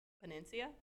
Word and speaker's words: panacea: I don't know this fourth one. Penancia? – Are you sure? – No, but we're going to go with that? – You're correct. – Ooh, I'm killing it, guys. Okay. And it's spelled P-A-N-A-C-E-A.